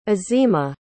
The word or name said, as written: azima